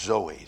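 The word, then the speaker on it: Zoe (ζωή): Z-O-E, pronounced Zoe, Zoe.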